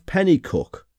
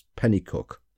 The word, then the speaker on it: Penicuik: Located near the town of Pennycook, now I had to look up how to pronounce this as it's spelt P-E-N-I-C-U-I-K. So I wasn't sure about that, but I did look it up and it's pronounced Pennycook.